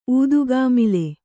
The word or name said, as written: ಊದುಕಾಮಾಲೆ